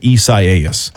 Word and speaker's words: Isaias: If you want to know how to spell it, spell Isaiah and then add an S at the end and then pronounce it Isaias.